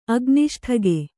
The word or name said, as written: ಅಗ್ನಿಷ್ಠಗೆ